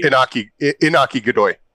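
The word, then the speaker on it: Iñaki Godoy: I think the cast, and I want to say his name right, is it Inaki? Inaki, who plays Luffy. – Inaki Godoy.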